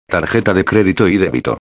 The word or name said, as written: tarjeta de crédito y débito